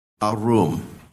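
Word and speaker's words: arum (ערום): And it's pronounced arum. Arum.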